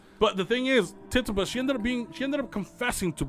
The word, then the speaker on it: Tituba: With Sarah Good, Sarah Osborne, and I think her name's pronounced Tituba. – Tituba. – I think it's right. Tituba.